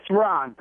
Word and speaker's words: Ron: It's Ron. – Ron? Excuse me. This is spelled J-U-A-N, is what my screener put up. So what does J-U-A-N spell? – R-O-N. – Okay. What does J-U-A-N spell? – Juan.